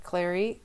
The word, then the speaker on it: Claure: I think I'm going to pronounce his last name correct. Clear. His last name is spelled C-L-A-U-R-E.